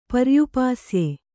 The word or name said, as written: ಪರ್ಯುಪಾಸ್ಯೆ